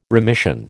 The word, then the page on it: remission: American English